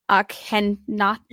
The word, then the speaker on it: Akhenaten: He's often called Akhenaten. – Yeah, Akhenaten. – That's Akhenaten. There it is.